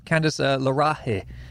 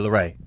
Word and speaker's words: LeRae: Candice LeRae is the correct pronunciation. No, LeRae.